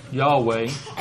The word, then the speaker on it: Yahweh: The letters are YHWH in our, if we were transliterating, translated in the American Standard Version as the word Jehovah, pronounced among the those that practice or call themselves Jews, Yahweh.